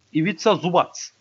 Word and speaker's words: Ivica Zubac: Nasıl okunuyor onu bir şey yapmam lazım. Ha, Ivica Zubac. Ha, tamam. Hı hı. Böyle bu şekilde okunuyor.